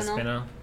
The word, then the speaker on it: Aspinall: Aspinall. Yep, so Alden Baudot versus Tom Aspinall. Pretty decent fight. That I know, you know, that was another KO. Another KO by big boys. Those are heavyweights. Tom Aspinall looked good.